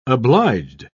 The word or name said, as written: obliged